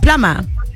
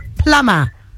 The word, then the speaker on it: plumber: Which letter is silent in the word plumber? – Uplamba. Oh, P. P. – P. Oh, my word. What is a circle in Zulu? – Oh, it's the B.